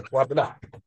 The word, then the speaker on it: Kwabena: Kwabena.